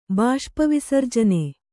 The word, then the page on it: ಬಾಷ್ಪವಿಸರ್ಜನೆ: Kannada